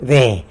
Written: de

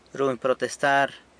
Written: runy protestar

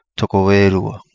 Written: Togo E Lua

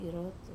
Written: rotu

